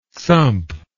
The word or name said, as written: sump